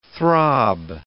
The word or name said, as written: throb